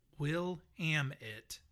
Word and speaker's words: Willamette: So this person says, so for the record, the correct pronunciation of Willamette, as it relates to the Oregon wine region, is Willamette. – Willamette. – As in Willamette, damn it.